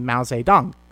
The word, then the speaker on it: Mao Zedong: Mao Zedong.